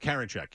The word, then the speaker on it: Karinchak: It's James Karinchak? – Karinchak, yeah.